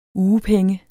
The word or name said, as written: UGEPENGENE